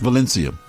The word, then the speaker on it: Valencia: It's V A L. E N C I A. Val- Valencia. there you go.